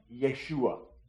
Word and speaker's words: Yeshua: Jesus is just a like anglicized way of pronouncing the Hebrew name Yeshua, which means Yahweh is salvation. The Lord is salvation. That's what the name Yeshua means.